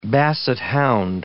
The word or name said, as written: basset hound